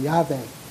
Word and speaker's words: Yahweh: And that actually is pronounced in Hebrew, Yahweh. As best we can tell.